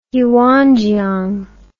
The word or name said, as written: yuanjiang